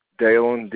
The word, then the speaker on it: Daylyn: Daylin, D-A-Y-L-Y-N, last name Miller.